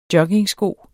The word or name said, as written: joggingsko